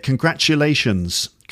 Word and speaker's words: congratulations: Isn't it interesting that Americans and Brits pronounce the word congratulations slightly differently? Listen to the way I say it. Congratulations. Congratulations. And Americans like congratulations. So it's more of a j sound.